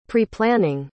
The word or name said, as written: preplanning